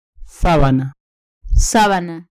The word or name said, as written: sabana